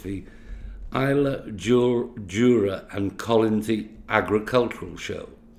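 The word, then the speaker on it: Islay, Jura and Colonsay: However, I'm going to ask David how he got involved with the show, and am I correct in the pronunciation of the show? – Good afternoon. Yeah, you're correct. It's the Isla Jura and Collinsy Agricultural Association show, which is on the 11th of August this year.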